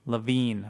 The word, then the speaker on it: Levine: Chad Taporsky on our live blog here saying, according to the pronunciation guide, it's Levine. Levine, okay.